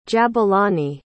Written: jabulani

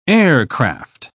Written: aircraft

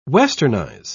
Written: westernize

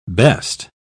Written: best

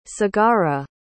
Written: sagara

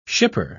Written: shipper

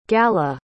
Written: galla

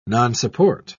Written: nonsupport

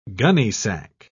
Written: gunnysack

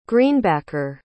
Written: greenbacker

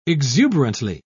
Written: exuberantly

